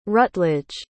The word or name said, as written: rutledge